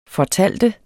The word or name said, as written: fortæller